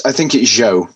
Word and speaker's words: Zhou: I think it's Zhou is his last name. – That is right.